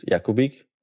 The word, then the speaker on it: Jakubik: My last name is actually, in Slovak, it's Jakubik. I have no idea how to pronounce that in English. – Just like you said it.